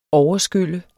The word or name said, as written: overskylle